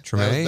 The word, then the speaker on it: Treme: Is it Treme? – Treme.